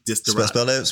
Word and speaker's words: desideratum: Distorotum. – Spell that, please. – Distorotum is spelled D-E-S-I-D-E. R-A-T-U-M.